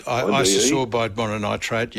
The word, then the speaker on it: Isosorbide mononitrate: One's ISOSORDIE. – Isosorbide mononitrate, yep.